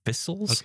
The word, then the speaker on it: Vissles: This one, it's a company called Vissels. I think I've pronounced that right. V-I-S-S-L-E-S. Vissels.